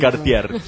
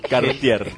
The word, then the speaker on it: Cartier: Me llama la atención que lo pronuncie tan mal, siendo que lo quiere defender tanto. – Cartier. ¿Cómo? Cartier. – Que hoy a la mañana la señora Ottaviano me dio una lección de francés.